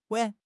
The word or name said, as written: wan3